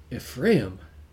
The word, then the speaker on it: Ephraim: And then he said, "Ephraim." So that's what he said. So that's obviously the pronunciation for Ephraim that would be in his language, in his Hebrew tongue.